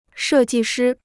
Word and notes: 设计师: shè jì shī

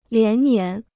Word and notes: 连年: lián nián

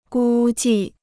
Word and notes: 估计: gū jì

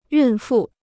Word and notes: 孕妇: yùn fù

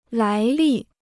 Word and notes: 来历: lái lì

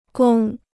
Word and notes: 宫: gōng

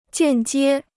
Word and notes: 间接: jiàn jiē

